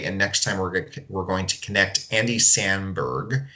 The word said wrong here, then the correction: Samberg: hopefully I'm saying that right. Andy Samberg, you know him from Saturday Night Live and uh, Brooklyn 911.